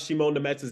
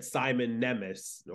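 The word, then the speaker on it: Šimon Nemec: Like, he knew how to pronounce Shimon Nemec's name before the actual New Jersey Devils team, because when they drafted him, they said Simon Nemec or like someone like that. – Shimon Nemec. – Yeah, and then you said it was Shimon Nemec, and then Shimon Nemec himself said that's how you pronounce it. You don't. It's not Simon Nemec or whatever, because I would say that's too Anglo-Saxon for a European of his type.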